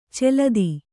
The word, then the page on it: ಚಾಲ್ತಿ: cālti